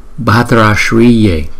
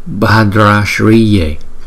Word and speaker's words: Bhadra Shriye: Kiên Đức Phật chúng ta sẽ phát âm là Bhadra Shriye, Bhadra Shriye, Bhadra Shriye, Bhadra Shriye, Bhadra Shriye, Bhadra Shriye, Bhadra Shriye, Bhadra Shriye.